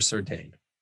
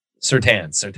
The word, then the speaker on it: Surtain: Gun to your head, him or Surtain? Surtain, sorry.